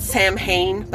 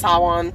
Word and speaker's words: Samhain: It's like the festival of they pronounce it Samhain, but it's pronounced Samhain.